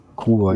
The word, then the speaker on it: Kruor: Dann kommen wir zu den Blood Raidern und da haben wir die, äh, wie spricht man es jetzt aus? Kruor? – Kruor, ja. – Kruor.